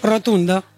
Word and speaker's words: rotunda: ¿cómo se dice rotonda en rumano? – Rotunda. Ah, claro, rotunda, pues con lo que es Ayuso.